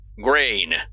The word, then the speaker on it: Granger: Granger, sir. Grain. The second G is silent.